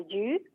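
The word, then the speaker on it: yu (鱼): Por supuesto, porque el pescado en chino se pronuncia como yu, y em, es muy parecida a la palabra sobrar, ¿no? – Entonces es un símbolo de abundancia.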